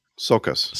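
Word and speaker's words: Csokas: Sokas. – Sokas with a C-S. I guess that's like Sokas.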